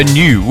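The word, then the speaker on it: Gnu: a New, with a silent G in that, and You. A Day in the Life of a Poo, a New, and You. And it's basically sort of, it's described as a laugh out loud guide to life on Earth, a fact book.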